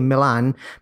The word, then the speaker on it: Milan: That's why they're pronounced in an English way, Milan, because they were founded by English people.